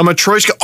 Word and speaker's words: Matryoshka: A Matryoshka.